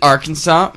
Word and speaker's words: Arkansas: Apparently, in Arkansas, it's a law that Arkansas must be pronounced Arkansas.